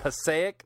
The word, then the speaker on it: Passaic: But the article, the big headline for the Huffington Post is 10-inch Pacu, testicle eating fish caught in how do you pronounce that? – Passaic. I'm from you. – New Jersey. I can help. – I knew you were. Yeah. Passaic, New Jersey.